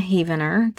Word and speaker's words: Heavener: My name is Melina Heavener. That is spelled heaven with an ER at the end.